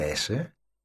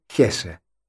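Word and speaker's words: Hesse: Εξαιρετικός ο συγγραφέας, ο οποίος δεν λέγεται Έσε, λέγεται Χέσε. – Χέσε, αλλά στην ελληνική, επειδή δεν ακούγεται τόσο. – Στα ισπανικά, ας πούμε, αν ήταν, δεν θα διαβάζονταν αλλά στα γερμανικά διαβάζεται.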